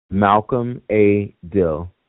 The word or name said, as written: Malcolm A. Dill